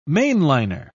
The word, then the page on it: mainliner: British English